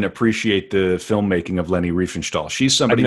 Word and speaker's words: Riefenstahl: Is that Riefenstahl? – Riefenstahl.